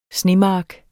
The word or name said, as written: snemark